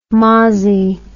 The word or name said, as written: mossie